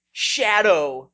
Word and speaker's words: Shado: There's a character named, spelled Shado, S-H-A-D-O, except pronounced Shadow on that.